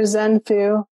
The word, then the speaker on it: Zenfu: Uh, yeah, I am on Facebook under Zenfu.